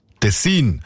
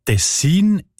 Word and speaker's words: Tessin: Die Variante mit der Betonung auf der ersten Silbe und mit kurzem I, also Tessin, ist seltener.